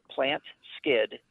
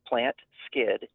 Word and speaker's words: PlantSkydd: And it's spelled weird, but it's called Plant Skid. It's one word, so PlantSkydd, Plant Skid.